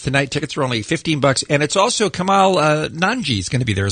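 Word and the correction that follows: Kumail Nanjiani: Oh, I know I always say his name wrong. – Some hilarious. Kamal Nanjiani is on the show.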